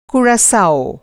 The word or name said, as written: कुराकाओ